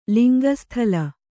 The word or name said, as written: ಲಿಂಗಸ್ಥಲ